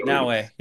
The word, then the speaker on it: Naoe: So it said play as Yasuke or play as, is it Naoe? I don't know how to say it, but N-A. – That's right, Naoe. Yeah. – Naoe.